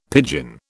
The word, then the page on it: pidgin: British English